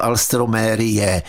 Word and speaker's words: Alstroemeria: Alstroemeria, no a čte se to alstromérie, spíš jako dlouze v prostředku to, ale to je jedno.